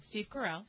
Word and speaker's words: Steve Carell: Steve Carell.